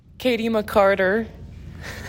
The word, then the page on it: Katy McCarter: KAY tee mih CAR ter